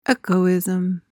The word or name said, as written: echoism